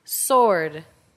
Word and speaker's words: sword: 읽을 때 W는 묵음이에요. 예를 들어 answer라 그러지 answer라고 안 그러는 것처럼 마찬가지로 이것도 W를 읽지 않습니다. 어떻게 읽죠? sword 이렇게 읽는 거지 이걸 sword라고 읽으면 안 됩니다.